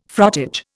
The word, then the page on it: frottage: Indian